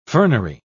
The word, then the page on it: fernery: British English